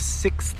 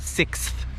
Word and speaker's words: sixth: Actually, there's a movie called The Sixth Sense. Can you pronounce that word? Sixth. Sixth. Very often, that's a hard sound. People have a hard time pronouncing it.